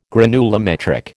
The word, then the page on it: granulometric: American English